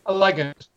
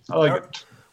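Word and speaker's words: Allegiant: The Allegant or Allegant? Yeah. – Allegant. A-L-A-G-N-T. Allegant.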